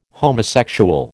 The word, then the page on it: homosexual: American English